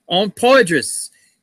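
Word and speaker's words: Poydras: Don't forget, Sunday at high noon in the Terror Dome on Poitras. Did I pronounce that right? – Yes, you did. – All right. Cool. On Poitras, high noon showdown with the Monsters of the Midway.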